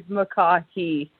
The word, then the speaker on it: McCaughey: My last name is McCaughey, yeah, because I was looking at it going, I think the second C is silent, but I'm not sure, so.